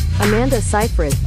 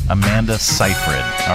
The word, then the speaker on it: Amanda Seyfried: Amanda Seyfried. – Amanda Seyfried.